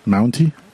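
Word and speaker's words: Mountie: Is it Mounty or Monty? – I don't know what you're talking about. – The Canadian. – Did you pronounce it wrong, too? Mounty. Oh, my God. – It's a Mounty. Mounty? Yes.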